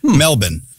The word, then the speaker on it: Melbourne: I never heard it pronounced correctly. And then all of a sudden, it was just over my head. Melbourne. – Yeah, but that's only for the locals. We got to say Melbourne.